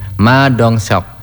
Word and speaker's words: Ma Dong-seok: Bacanya benar enggak ya? Ma Dong-seok. Oh iya, benar. Ma Dong-seok. Nah, ini bacanya benar kayaknya.